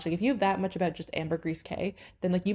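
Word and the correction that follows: Caye: must have, I think it's actually key now that I'm thinking about it.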